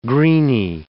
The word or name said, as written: greeny